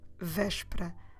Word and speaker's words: Véspera: And it's still Véspera. Como se escreve Véspera? V é com acento S P E R A Véspera.